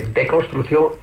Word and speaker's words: deconstrucción: Ahí tiene el valor esta palabra de deconstrucción, insisto, sin la S de la primera sílaba.